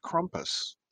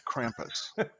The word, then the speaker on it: Krampus: Yeah, and so if I could bring in my erudite doctor knowledge, it's pronounced Krampus, not Krampus. – Krampus. – Krampus, okay. Thank you. Yeah, like tea and crumpets, but Krampus. Oh, that is like, you've been Krampus! – Or like Rumpus Room.